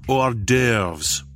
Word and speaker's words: hors d'oeuvres: And what about all the times when I need snacks and horse stewers? – It's pronounced hors d'oeuvres.